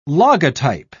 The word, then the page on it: logotype: British English